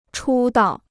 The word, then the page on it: 出道: chū dào